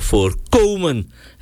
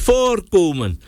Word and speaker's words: voorkomen: Je moet dat klemtoon boem vast, je moet zeggen voorkomen en niet zeggen voorkomen. Want voorkomen is, ja, er zijn bauxiet voorkomens. Ja, dat komt voor in de bodem. Maar het voorkomen, met die klemtoon op die O, dat tweede, lettergreep, daar moet je dat ding preventen.